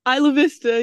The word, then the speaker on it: Isla Vista: So the place, I mean, looking at Santa Barbara, and I'm going to pronounce this wrong, is it Isla Vista? – Isla Vista, yeah.